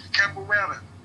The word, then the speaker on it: capoeira: studied the martial art capoeira. I hope I'm pronouncing that right. – Capoeira.